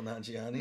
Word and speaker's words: Nanjiani: No, I was thinking I would cast Camille Nanjiani. How do you say his last name? – Camille Nanjiani.